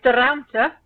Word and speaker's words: de Ruimte: yes, de Ruimte is how you pronounce it.